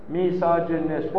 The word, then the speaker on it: misogynist: Misogynist. Whoa, four syllables.